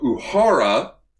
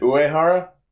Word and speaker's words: Uehara: Sozo Uhara, not Uhara, I've been pronouncing his name wrong this entire year. I'm terribly sorry. – Is it Uehara? – I think the E silent, so it's like Uhara? – If there's an E, it's Uehara. – Yeah. That's how you pronounce that.